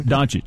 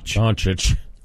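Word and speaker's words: Dončić: How do you say his last name? – Doncic. – Doncic.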